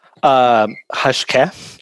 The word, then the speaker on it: Hashké: But what I found, you know, striking about, and correct me if I'm butchering the name, Hashké. – Hashké is, you know, there's that accent on the E,